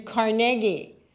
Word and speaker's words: Carnegie: Andrew Carnegie, which I now know is how you pronounce it. It's not Carnegie Hall, it's Carnegie Hall.